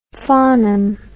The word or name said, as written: farnham